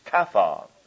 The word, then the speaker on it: kaphar: If you want the actual word in the Hebrew, it's kaphar, spelled in our English letters, K-A-P-H-A-R. Kaphar, K-A-P-H-A-R. And kaphar sounds like the word cover, and that's what it is.